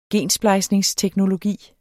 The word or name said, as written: gensplejsningsteknologi